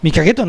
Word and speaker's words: 御影: そもそも御影という地名は 3世紀に神宮皇后がこの湧き水で化粧直しをした、その時に皇后の姿が水に映ったということからね、御影、御影と書いて御影と読むんですけれども、御影と名付けられたわけなんです。そういう古代から名水の里として知られてきたわけなんですよ。 – うん、時はその水を沸かしていると。 – その通り。もう御影の名水を沸かしている宮水銭湯は今やこの時は湯一軒しかないんですよ。